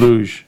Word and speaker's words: luge: Luge? Is that how you say it? – Luge.